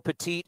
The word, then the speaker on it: Petit: Yesmero Petit is not Pettit, unless we got Andy Pettit.